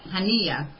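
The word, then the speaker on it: Haniyeh: You have an anti-pragmatism division led by this guy named Meshaal, who disagreed with Prime Minister Hanina. Let me make sure I said his name right. Hania.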